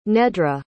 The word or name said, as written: nedra